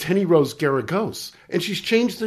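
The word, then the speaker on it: Geragos: My mom used to say, it's Garagos rhymes with asparagus.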